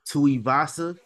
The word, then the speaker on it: Tuivasa: Was it Tuivasa? – Did I say that right? Yeah.